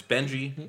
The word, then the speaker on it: Benji: But, like, my name's Benji, B-E-N-J-I. Every letter makes a sound. And you see people whose names are like Aaliyah, and it's spelled like A-A-L-I-Y-E-I-G-H-A or something like that, which I'm not even making fun of it.